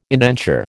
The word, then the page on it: indenture: American English